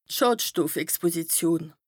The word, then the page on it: d' Schàdstoffexposition: Bas Rhin